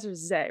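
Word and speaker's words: Zei: And which impresses and mystifies this stranger who introduces himself as Professor Zay, right? That's how you pronounce it? I'm trying to remember. – Yeah.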